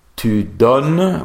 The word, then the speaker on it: donnes: Je donne, tu donnes, with the S, but we don't pronounce it.